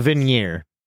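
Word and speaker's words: Vigenère: So, anyway, if you take the Instagram post description, you find out that this is a Visioneer. Is that how you say it? A Visioneer cipher? And when you decode this using Mickey Finn as the key. – Oh, the Veneer.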